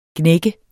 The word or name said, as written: gnægge